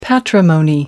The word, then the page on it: patrimony: American English